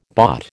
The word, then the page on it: bought: American English